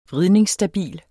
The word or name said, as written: vridningsstabil